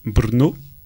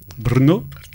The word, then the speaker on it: Brno: Brno. Ah, tu prononces bien. Brno.